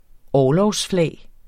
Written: orlogsflag

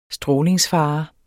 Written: strålingsfare

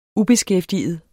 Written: ubeskæftiget

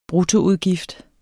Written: bruttoudgift

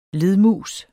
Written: ledmus

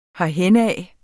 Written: herhenad

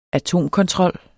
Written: atomkontrol

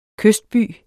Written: kystby

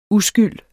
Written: uskyld

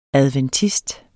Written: ADVENTIST

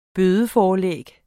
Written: bødeforlæg

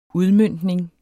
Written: udmøntning

